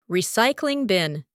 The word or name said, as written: recycling bin